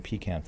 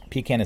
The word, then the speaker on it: pecan: Kaleido Rice Krispies, Puffins cereal, Spider-Man cereal from Ralston, and Temptations in both French almond, French vanilla almond, and honey roasted pecan flavors. Pecan is a proper pronunciation. Pecan is some sort of aberration from other parts of the country.